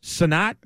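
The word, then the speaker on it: Cenat: Sinat? – It's spelled Sent, but I think it's Sinat.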